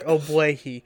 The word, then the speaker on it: Ogbuehi: Abwe- I knew Abwehi. Abwehi. – Yes, Cedric Ogbuehi.